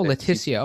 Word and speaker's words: Leticia: L-E-T-I-C-A. – Leticia. Oh, Leticia. Okay. Mm-hmm. Just like a name.